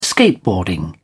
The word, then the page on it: skateboarding: British English